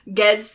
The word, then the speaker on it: Ged: Archipelago of Earthsea, and centers on a young mage named, I always think it's Jed, but she says in the episode Ged, so I'm gonna say that that's how you pronounce it.